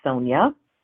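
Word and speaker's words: Sonja: For many people, Sonia is spelled with an I or Y, but my name is spelled with a J, S-O-N-J-A. Because of that J, some people pronounce it in ways that are not the pronunciation of my name. My name is Sonia, spelled with a J, even though it doesn't always sound like a J should be there.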